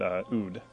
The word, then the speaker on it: oud: That's right, oud or oud. If you give it an Arabic pronunciation, if you put the definite article on it, the lute is called in Arabic al oud, and you can easily see how that turns into lute in English.